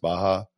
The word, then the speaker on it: Baja: And before anybody gets up Twitter or whatever saying that you pronounced it wrong, I know it's pronounced Baja, but Baja just sounds better.